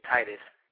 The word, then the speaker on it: Titus: It's going to be Titus.